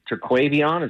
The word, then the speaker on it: Terquavion: Terquavion, is that how he yes.